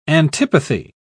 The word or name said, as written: antipathy